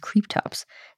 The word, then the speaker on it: Cryptops: And now onto our dinosaur of the day, Cryptops. I've also heard it pronounced Creeptops.